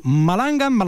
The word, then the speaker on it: Malangan: A ver si, dilo tú, Malangan, ¿cómo se pronuncia esto? – Malangan. – De malandrín, Malangan.